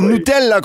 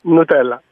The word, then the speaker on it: Nutella: Donc, c'est Nutella qu'on devrait prononcer. – Nutella, oui.